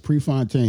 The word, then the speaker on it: Prefontaine: I got it right. Woohoo! Yeah. I didn't know how to pronounce it. – Yeah, that's right. Prefontaine, he was an ex-runner.